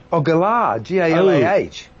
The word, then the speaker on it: galah: Oh, galah. All right, I was pronouncing it wrong.